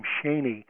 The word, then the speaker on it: Schoen: Actually, the family pronounces the name Shaney.